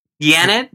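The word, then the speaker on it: Yanet: Yannette? Was that one of the roommates in Three's Company? – Yes, it's the Spanish pronunciation, Yannette Garcia.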